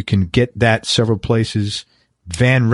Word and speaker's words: Van: Ron, his last name is Van Herpen, V-A-N. Am I saying that right? – Van. Van. – Okay. Yeah. Ron Van Herpen. It's V-A-N-H-E-R-P-E-N.